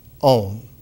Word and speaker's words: Om: And that Om, when it is pronounced Om, Om, Om, it has a quality of resonantly emerging into something that in the ancient Upanishads was always put there.